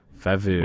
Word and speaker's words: Fauve: I picked Fauve. – Fauve. – Which I'm sure is how it's pronounced. – Yeah, that sounds accurate.